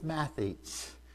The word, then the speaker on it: mathetes: It's mathetes. Okay, of course, I'm a math teacher, so it's spelled M-A-T-H-E-T-E-S, all right? So it's mathetes.